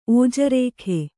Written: ಓಜರೇಖೆ

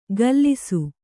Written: ಘಲ್ಲಿಸು

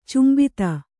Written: ಚುಂಬಿತ

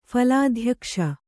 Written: ಫಲಾಧ್ಯಕ್ಷ

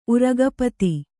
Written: ಉರಗಪತಿ

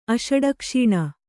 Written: ಅಷಡಕ್ಷೀಣ

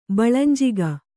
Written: ಬಳಂಜಿಗ

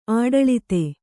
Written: ಆಡಳಿತೆ

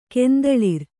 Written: ಕೆಂದಳಿರ್

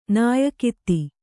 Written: ನಾಯಕಿತಿ

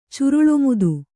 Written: ಚುರುಳೊಮುದು

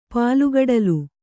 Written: ಪಾಲ್ಗಡಲು

